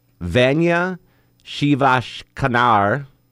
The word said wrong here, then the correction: Vanya Shivashankar: I'm sure you butchered both of them. – Yeah, I absolutely did. So they're the co-champions. Vanya Shivashankar. Shankar? Yeah, I got that one.